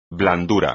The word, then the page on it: blandura: espagnol